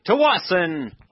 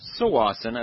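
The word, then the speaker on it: Tsawwassen: We'll be in Tawasin, or Sawasin, as it's properly pronounced.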